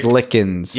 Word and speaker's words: Merzlikins: Did you guys know that Elvis Merzlifskins was picked by the fifth round pick? Oh, that's my pronunciation, not Haroon's, by the way. Picked by the fifth round pick. – Merzlikins.